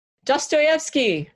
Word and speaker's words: Dostoevsky: Now, you mentioned the Russian writer, and I'm not sure if I'm pronouncing this right, Dostoevsky? – Dostoevsky.